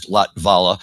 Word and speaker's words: Lavatla: And thank you for correcting me on the pronunciation of his last name, which is Lavatla. It just was always difficult to say because you got that T and V right next to each other without a vowel.